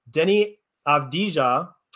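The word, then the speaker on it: Deni Avdija: So my underrated pickup here is going to be Denny Avdija. Correct me if I'm wrong in that pronunciation. – I think you got it.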